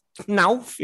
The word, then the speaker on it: Knauff: Uh, Jude Bellingham and Ansgar Knauff is how you pronounce his name.